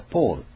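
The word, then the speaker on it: Paul: 英語ではポールっていう発音になるんですね。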